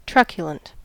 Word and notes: truculent: American English

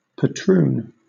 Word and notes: patroon: British English

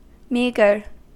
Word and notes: meagre: American English

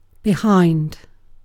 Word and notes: behind: British English

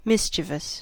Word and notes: mischievous: American English

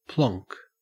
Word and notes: plonk: Australian English

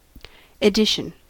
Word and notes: edition: American English

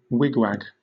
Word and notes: wigwag: British English

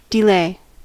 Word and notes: delay: American English

